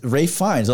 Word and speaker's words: Ralph Fiennes: It's Ralph Fiennes.